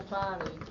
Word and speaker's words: Fante: Che lui pronuncia Fane.